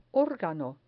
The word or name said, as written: organo